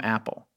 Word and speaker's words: Apol: called himself Apple, A P O L, pronounced Apple.